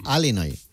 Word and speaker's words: Illinois: Se pronuncia Illinois. – Sí, perdón, perdón, sí. – Illinois. – Illinois. Pues yo digo Illinois, si no, no entendemos todo. Porque como diga Illinois, nadie lo entiende.